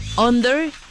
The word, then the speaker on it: UNDR: Se llama así, Under, y se escribe UNDR, para que lo vayan buscando, Under.